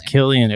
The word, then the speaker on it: Cillian: Yeah, we found out Killian. It was Killian. It really fucked me up. – Wow. – Yeah. I've been wrong all along.